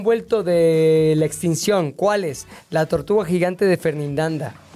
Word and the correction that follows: Fernandina: Qué bueno. Que lo dije mal, perdón. Lo dije muy seguro, pero lo dije perfectamente mal. La tortuga gigante de Fernandina.